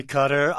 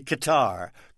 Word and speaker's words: Qatar: With apologies to Ira Gershwin, you say Qatar, I say Qatar. Qatar, Qatar, let's call the whole thing off.